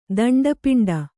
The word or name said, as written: ದಂಡಪಿಂಡ